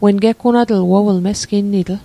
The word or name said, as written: geggunatl